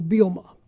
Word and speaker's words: Bielema: So I went backtrack to talk to Kent a little bit about it, and Kent said, quote, Brett says the second E is silent. So Bielema. – Bielema.